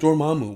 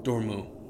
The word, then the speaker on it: Dormammu: But I also think that Dormammu. Why do you hate taking syllables out of words? – What did I say? – Dormu. – I didn't say Dormu. Dormammu.